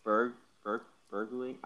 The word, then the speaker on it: Bergling: There's no T. It's Berling, like Bergling.